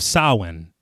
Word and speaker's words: Samhain: He says, Halloween, the festival of Samhain.